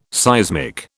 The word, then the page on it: seismic: British English